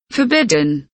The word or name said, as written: forbidden